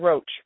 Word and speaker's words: Roche: It's Alana Roach, R-O-C-H-E.